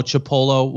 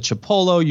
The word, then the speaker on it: Chipolo: I don't see them getting in on it, but, you know, Chipolo will be on it. Am I saying that right? – No. Here, this is how you say it. Chipolo.